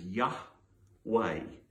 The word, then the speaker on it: Yahweh: And the soundings of it, it's in broken into two syllables. It's a YHWH. Two syllables is Yahweh is the way, or Yahweh might be nearer to how it would have been originally said.